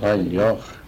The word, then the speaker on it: Reidar: De sa noe mye reier før i tida. – Ja. – Reier eller reier. Men det som er nå, gjerne både skrive, uttale reider.